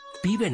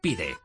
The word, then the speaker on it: PIDE: PIBE no. PIDE, con D de Dinamarca.